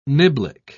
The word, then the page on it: niblick: British English